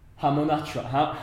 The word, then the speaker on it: Hamunaptra: Hamunaptra. The City of the Dead. – That's how you pronounce it, stutter included. That's actually the correct pronunciation.